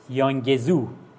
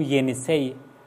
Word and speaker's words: ینیسی: غیر از این هم سنگ نوشته هایی در اطراف رودخانه ینیسی، یا اونطوری که در فیلم امپراتوری دریاها دیدیم و تلفظ میکردن یانگزو، اونجا یانگزو میگفتند، همون ینیسی هست.